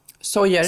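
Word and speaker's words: Sóller: Uttalas det så? Sojer. – Sojer.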